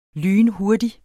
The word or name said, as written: lynhurtig